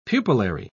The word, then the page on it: pupillary: British English